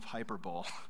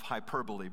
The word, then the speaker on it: hyperbole: But I also realized that some of you, English is not your first language, and so you actually, the right way to say that is the epitome of hyperbole, but the way that you spell it, we're sorry for our language.